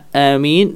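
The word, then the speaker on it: آمين: Qala al-ulama'u afsahuha amin bil maddi wat takhfifil mim. ya, ya, amin yang paling fasih adalah dengan amin dengan mad, ya, dengan mad pada alif maupun mim,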